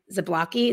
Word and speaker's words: Zablocki: And it talks more about sociologist Benjamin Zablocki.